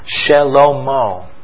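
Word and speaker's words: Shlomo: The pronunciation of that is Shelomo.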